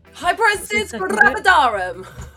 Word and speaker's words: hyperemesis gravidarum: Hyperemesis gravidarum.